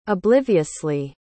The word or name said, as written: obliviously